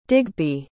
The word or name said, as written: digby